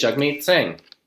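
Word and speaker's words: Jagmeet Singh: Rhymes with hug meat. Oh, yeah. My apologies, Jugmeet Singh.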